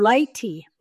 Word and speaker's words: Lighty: Lighty, like the light bulb.